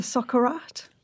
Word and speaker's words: socarrat: Okay, let's talk about something that I love, and I'm probably going to mispronounce this, the socarrat.